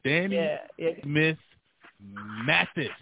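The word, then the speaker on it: Danne Smith Mathis: I didn't call the name wrong, but it's Danny Smith Mathis, folks, M-A-T-H-I-S.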